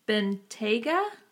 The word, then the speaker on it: Bentayga: It was a Bentley Bentayga. – You're asking the wrong person. – No, I'm telling you. I'm like, am I pronouncing it correctly? Yes.